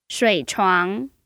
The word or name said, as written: shui3chuang2